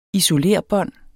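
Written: ISOLERBÅND

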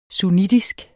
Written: sunnitisk

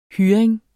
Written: hyring

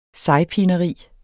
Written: sejpineri

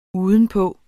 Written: udenpå

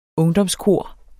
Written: ungdomskor

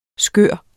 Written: skør

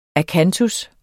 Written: akantus

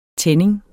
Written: tænding

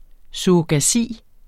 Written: surrogasi

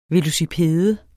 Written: VELOCIPEDERNES

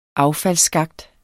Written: affaldsskakt

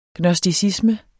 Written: gnosticisme

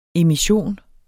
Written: emission

